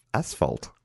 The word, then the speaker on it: asphalt: There's no H in that spot. It's asphalt.